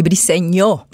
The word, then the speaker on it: Briceño: Est-ce que je le prononce bien ton nom? Oui, c'est Brissegno. Ah! la petite vague sur le N.